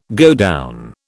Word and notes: godown: British English

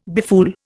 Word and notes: befool: Indian

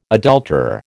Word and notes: adulterer: American English

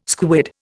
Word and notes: squid: Indian

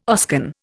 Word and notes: Oscan: Indian

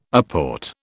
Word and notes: apport: British English

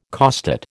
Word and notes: costate: American English

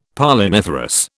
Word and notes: Polliniferous: British English

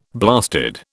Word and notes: blasted: British English

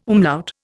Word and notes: umlaut: Indian

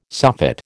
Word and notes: soffit: American English

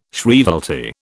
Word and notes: shrievalty: British English

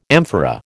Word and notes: amphora: American English

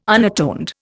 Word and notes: unatoned: Indian

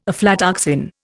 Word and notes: aflatoxin: Indian

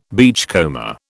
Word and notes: beachcomber: British English

